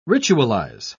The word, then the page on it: ritualise: British English